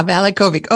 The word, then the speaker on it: Valachovic: Ah, Valachovic.